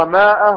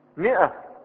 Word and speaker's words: مئة: ألف بعد الميم، ولا وهل ننطقها ماء ولا ننطقها مئة؟ مئة، والألف لا أثر لها في النطق.